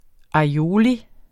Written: aioli

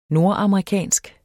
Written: nordamerikansk

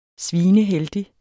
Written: svineheldig

